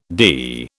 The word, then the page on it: dee: British English